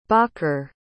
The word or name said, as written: bakr